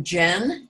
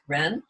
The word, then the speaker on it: Jen: And one more point, related, sort of similar, there's a Confucian term, Jen, J-E-N, or some people pronounce it Ren.